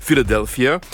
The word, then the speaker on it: Philadelphia: qu'on prononce en anglais Philadelphia.